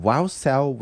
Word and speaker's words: Wausau: Wausau, Wins, Wisconsin.